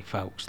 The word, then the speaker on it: Fawkes: Pues sí, Fawkes, tío. – Fawkes. – Fawkes.